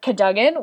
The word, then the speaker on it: Cadogan: Apparently his name is Sir Cadogan, which I forever pronounce Cadogan.